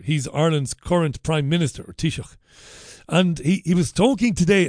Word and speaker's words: Taoiseach: The Irish Taoiseach, I used to get hammered years ago when I was on Irish radio for not pronouncing Taoiseach properly. Taoiseach. We used to say Taoiseach. You can't say that. You've got to say Taoiseach.